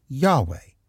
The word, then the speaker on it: Yahweh: I Am who I am is YHWH in Hebrew and pronounced Yahweh.